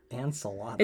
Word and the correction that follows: Enceladus: No, I think you're wrong. Can you spell it? – Enceladus. – I think it's Enceladus. – Enceladus. Yeah. That makes way more fucking sense.